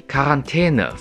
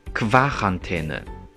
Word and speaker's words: Quarantäne: 注意，die Quarantäne 发音特殊，不是 Quarantäne。